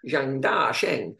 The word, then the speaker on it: Zhang Da Sheng: because with the Chinese names, you have to say it like Zhang Da Sheng is how it's written, but it's up or down. So actually, it's Zhang Da Sheng, if you say it correctly, but you can't say it correctly as an Englishman in the middle of a commentary flow.